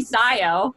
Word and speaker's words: Sio: No, but my friends who think that my mom is like so hilarious and ridiculous in her own ways started calling me Sio, like S-I-O, like just to like pick it, you know, be like, here, we made a nickname.